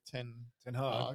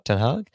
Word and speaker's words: Ten Hag: Eric Ten Hag. – Ten Hag.